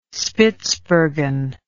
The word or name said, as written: Spitsbergen